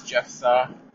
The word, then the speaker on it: Jephthah: And his name is Jephthah, or Jephthah. I'm sure there's a way to pronounce it in Hebrew that or Aramaic, that I'm just not going to be able to do. Jephthah, something like that.